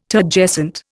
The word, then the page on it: Turgescent: Indian